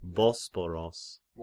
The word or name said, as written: Bosporos